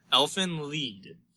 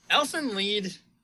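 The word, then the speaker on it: Elfen Lied: I believe we figured this out earlier, how it is pronounced. Elfin Lied. And what was the German? It's German. What was the It's German, German for elf song.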